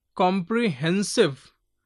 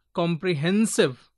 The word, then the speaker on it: comprehensive: Example, if I say economic, so economic, E-C-O-N-O-M, N-O-M is given more stress in this syllable, economic. Comprehensive, hen is given little more, comprehensive. So S-I-V-E, end with S-I-V-E, before S-I-V-E, hen is there, comprehensive. Apprehensive, decision, incision, musicians, mathematician, attention. We say attention. So last is T-I-O-N, before T-I-O-N, double T-E-N.